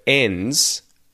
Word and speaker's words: ends: So you will have noticed, number one, that the word ends, the D isn't pronounced. So when we have a word that ends with N-D-S, the D gets dropped and it's just N-S.